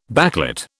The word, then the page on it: backlit: British English